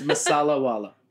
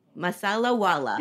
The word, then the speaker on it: Masalawala: It's Masalawala. – Masalawala. – You got it.